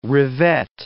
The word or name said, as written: revet